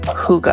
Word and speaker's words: hygge: Okay, you talked about the Danish tradition of hygge. Am I pronouncing that right? – Yes. – That's H-Y-G-G-E, that word that, like, went viral a couple years ago, and I never really knew how to pronounce it.